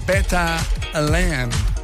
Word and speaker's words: Beta Land: Là, on vient d'entendre Mike Domero avec Beta Land. Prononcer comme ça, c'est correct, ouais.